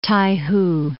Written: Tai Hu

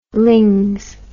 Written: lings

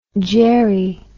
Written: geri

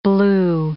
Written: blew

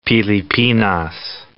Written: Pilipinas

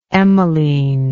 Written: Emmeline

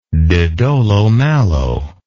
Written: de dolo malo

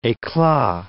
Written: eclat

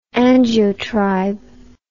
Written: angiotribe

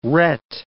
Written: ret

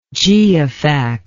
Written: geofact

